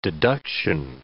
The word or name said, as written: deduction